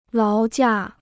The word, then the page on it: 劳驾: láo jià